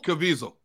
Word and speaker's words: Caviezel: Caviezel.